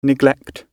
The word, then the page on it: neglect: British English